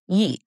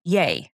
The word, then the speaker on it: Ye: Also, earlier this week, I said Kanye West's new name is pronounced Ye, like how it's spelled. Apparently, it's Ye, like, yay, other music exists, so we can all stop listening to his.